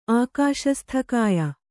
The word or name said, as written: ಆಕಾಶಸ್ಥಕಾಯ